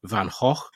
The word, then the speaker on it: Van Gogh: So Van Gogh or Van Gogh, Van Gogh, Van Gogh, people pronounce his name differently in different places. I think the proper pronunciation is Van Gogh, as they say where he's from.